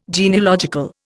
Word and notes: genealogical: Indian

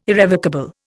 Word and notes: irrevocable: Indian

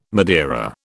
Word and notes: Madeira: British English

